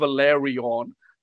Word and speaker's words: Velaryon: So, okay, so we just looked it up, and they are called Valerion, like VELARYON, but not Valerians.